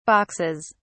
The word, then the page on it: boxes: British English